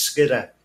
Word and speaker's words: Skiddaw: If you read it in the spelling, it's S K I D D A W and people pronounce it or, but here it's Skidder. It's it's just those language variations.